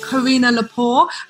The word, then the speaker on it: Carina Lepore: And today I have a very special guest, the Apprentice winner 2019, the wonderful Carina Lepore.